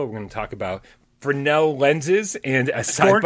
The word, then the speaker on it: Fresnel: As sci-fi game cat Henry says, remember the S is silent in Fresnel, like the S in Gamescast.